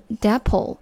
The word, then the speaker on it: depot: 接着来看最后一个名词，depot，也可以读成 depot。要注意这个单词最后的 t 不发音，直接读成 depot。